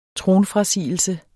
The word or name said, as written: tronfrasigelse